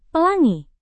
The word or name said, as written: pelangi